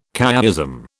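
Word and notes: Couéism: British English

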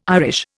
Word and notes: Irish: Indian

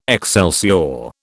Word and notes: excelsior: British English

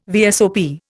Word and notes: VSOP: Indian

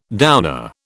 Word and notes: downer: British English

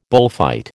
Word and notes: bullfight: American English

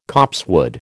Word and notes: copsewood: American English

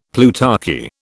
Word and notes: plutarchy: British English

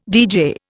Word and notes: deejay: Indian

